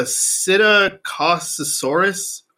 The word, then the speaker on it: Psittacosaurus: The dinosaur whose butthole they preserved and they found and preserved is the first letter is P, but I think it's a silent P. It's a Psittacosaurus, which I've never heard of before.